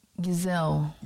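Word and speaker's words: Gisele: How do you pronounce her name? Gishley? Giselle? – Gazelle. – Gazelle, like a gazelle, like a giraffe, yeah.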